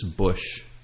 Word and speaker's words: Busch: He must be a family member of the Bush family. But okay. Bush is spelled B-U-S-C-H.